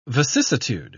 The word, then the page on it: vicissitude: British English